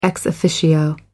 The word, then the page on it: ex officio: American English